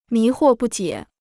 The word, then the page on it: 迷惑不解: mí huò bù jiě